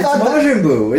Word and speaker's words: Majin Buu: Mojang Buu? – Majin Buu, isn't it? I said it right.